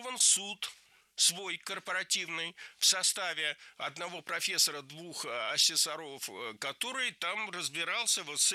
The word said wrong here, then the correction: асессоров: Лучше сказать не так, не асессоров, а асессоров. В слове «асессор» ударение на втором слоге сохраняется во всех формах.